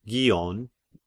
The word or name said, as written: Gion